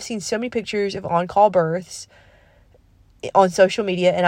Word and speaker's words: en caul: And if you don't know what on call means, on call is spelled E-N-C-A-U-L. It's a French word.